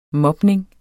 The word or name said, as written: mobning